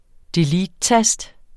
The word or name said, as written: deletetast